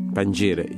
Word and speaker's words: Pangira: Pangira, did I pronounce that right? – Pangira, yes, Pangira.